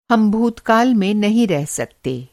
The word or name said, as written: भूतकाल